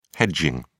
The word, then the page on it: hedging: British English